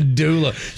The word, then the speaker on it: doula: A doula.